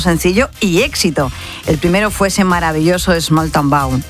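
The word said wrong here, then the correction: Smalltown Boy: Small Town Bow. Hoy voy, hoy se lo digo bien. – A ver. – Small Town Boy.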